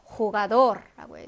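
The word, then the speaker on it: jugador: 강한 히읗 발음입니다. 그래서 호가돌라고 해서 요거는 이제 강한 히읗 발음이 되고요.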